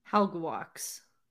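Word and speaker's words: Halbgewachs: It's H-A-L-B-G-E-W-A-C-H-S. You said it was Halbgewax? – Halbgewax. – Okay, so the first four letters you're combining, and then the last six you're combining.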